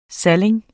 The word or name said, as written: saling